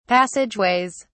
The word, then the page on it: passageways: British English